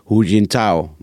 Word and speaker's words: Hu Jintao: Want we hebben die beelden gezien laatst van de voorganger, hoe die Hu Jintao, zeg ik dat goed? – Ja, uitstekend.